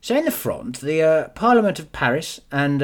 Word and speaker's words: Fronde: Not the Fons, the Fronde. Do you want me to spell it for you? – Yes, please. – F-R-O-N-D-E, silent E, unless it's Fronde. – But I don't know, if it's not got an acute accent on, then it's silent, it's Fronde.